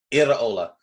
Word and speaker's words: Iraola: And by 3 p.m., they had announced their new manager, whose name, I'm going to go with Andoni Iraola. I'm going to allow you to correct me on that. – It's pretty, you know, close, to be fair.